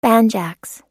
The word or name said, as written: banjax